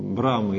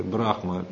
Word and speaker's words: Брахма: Это Шива-разрушитель Брама или Брахма, пишется Брахма, он все творит, и Вишну все поддерживает.